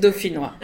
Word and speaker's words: Dauphinois: Dauphinois.